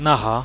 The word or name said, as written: naha